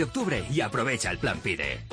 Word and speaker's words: PIDE: PIBE no. PIDE, con D de Dinamarca.